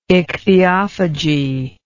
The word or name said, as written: ichthyophagy